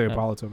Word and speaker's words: Hippolyta: So one of the, I don't know if you guys know this, but one of the myths was that Hippolyta. Is that how you say her name? – Yeah, her mother. I say Hippolyta, but yeah.